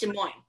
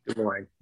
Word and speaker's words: Des Moines: I can never pronounce Is it Des Moines or Des Moines? It's Des Moines, right? – Des Moines. Yeah.